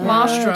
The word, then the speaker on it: colostrum: Colostrum. – Whoa. Colostrum.